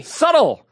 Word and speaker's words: subtle: Subtle. – Subtle. – Toll. I'm saying toll. – The B Manhattan. – The B is subtle. – The B is very subtle. It's a silent B.